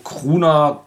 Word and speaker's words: Krvna: Äh, die Band nennt sich Krona, also K-R-V-N-A, ja, ausgesprochen Kruna, Krona.